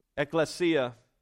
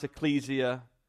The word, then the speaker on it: ekklesia: It's ekklesia, or you may have heard it pronounced ecclesia, or you've heard ecclesiology, and that's the study of the church.